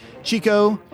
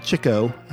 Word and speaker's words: Chico: Chico or uh, Chico, uh, depending on how you say it. I think the brothers said Chico, but they got pronounced the other way a lot.